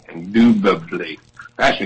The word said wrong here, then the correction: indubitably: He can't spell it the way he said it. I-N-D-U-B indubitably. I-N-D-U-B-I-T-A-B-L-Y.